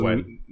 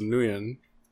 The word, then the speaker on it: Nguyen: Okay, I thought it was Nguyen. – That's like if you read it out loud, that's how it sounds like Nguyen. – Mm-hmm. – But it's pronounced Wen.